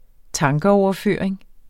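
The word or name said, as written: tankeoverføring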